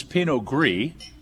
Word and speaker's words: Pinot Gris: Well, the next wine that we're going to have, which is Pinot Gris, and that's spelled Pinot, we know P-I-N-O-T. Gris is spelled G-R-I-S.